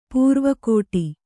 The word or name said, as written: ಪರ್ವುಗೆಡು